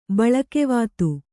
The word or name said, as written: ಬೆಳುಗವತೆ